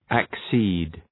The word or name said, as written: accede